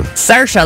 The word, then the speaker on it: Saoirse: Saoirse, like inertia.